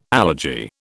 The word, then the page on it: allergy: British English